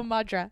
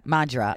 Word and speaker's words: Madra: How do you say it in Irish? Mandra. Oh, Madra. Madra.